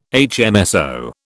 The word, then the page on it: HMSO: British English